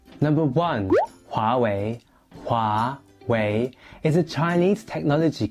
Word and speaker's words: Huawei: Together it's pronounced Huawei, as in Huawei.